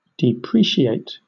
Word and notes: depreciate: British English

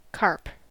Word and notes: carp: American English

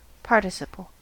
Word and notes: participle: American English

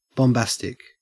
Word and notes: bombastic: Australian English

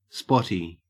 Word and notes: spotty: Australian English

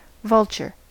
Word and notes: vulture: American English

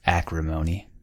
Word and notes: acrimony: American English

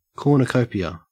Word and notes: cornucopia: Australian English

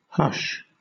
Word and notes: hush: British English

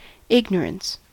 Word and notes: ignorance: American English

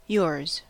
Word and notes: yours: American English

